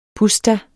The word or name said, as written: PUSZTAERNE